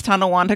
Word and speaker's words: Tonawanda: Andrew did pronounce Tonawanda correctly.